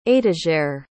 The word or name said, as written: étagère